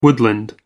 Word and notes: woodland: American English

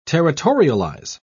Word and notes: territorialize: British English

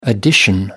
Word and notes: addition: American English

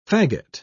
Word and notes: fagot: British English

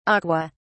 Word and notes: aquae: British English

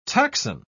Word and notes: taxon: British English

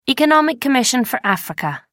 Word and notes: economic commission for africa: American English

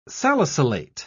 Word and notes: salicylate: British English

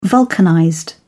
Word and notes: vulcanized: British English

